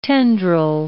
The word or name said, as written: tendril